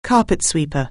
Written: carpet sweeper

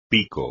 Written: pico

